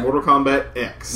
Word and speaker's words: Mortal Kombat X: That's fine, but I just read an article from Ed Boon who clarified it's Mortal Kombat X. – Yeah, well, he's pronounced EX. – Well, if it's Japanese, it's, you know, it'd be Mortal Kombat Cross.